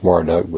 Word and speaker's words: Marduk: It's pronounced Marduk, but spelled like Merodach, M-O-R-O-D-A-C-H, pronounced like Marduk, M-A-R-D-U-K, in human form.